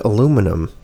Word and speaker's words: aluminum: You're mispronouncing the word aluminium. – No, I'm correctly pronouncing the word aluminum.